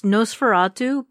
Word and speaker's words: NOS4A2: But I did binge watch, the show, and it's Nosferatu, but it's spelled NOS4A2.